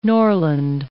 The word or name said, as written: Norland